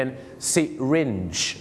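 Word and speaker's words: syringe: คำว่าเข็มฉีดยาเขาออกเสียงว่าซิริจนั่นเองครับซิริจเหมือนกับนักร้องนะครับโรสซิรินทิปนั่นเองครับแต่คุณใส่เป็นโรสซิริจนั่นเองครับผมอย่าลืมนะครับคุยพี่น้องชาวไทยไซลิงไม่ว่านะครับแต่ถ้าฝรั่งมาก็ต้องเปลี่ยนเป็นซิริจ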